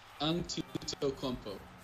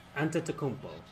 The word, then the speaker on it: Antetokounmpo: Yeah, it's Antetokounmpo. – Antetokounmpo. – Antetokounmpo. – Like five syllables.